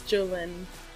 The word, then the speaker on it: Jolynn: That's not how you say your name, is it? – It's Jolynn.